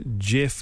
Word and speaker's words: GIF: And is it pronounced GIF or GIF? Looks like one of us is right. – I thought this debate was settled. I thought that GIF with a hard G had won.